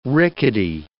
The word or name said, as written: rickety